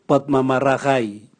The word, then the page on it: bwatmamaragai: Raga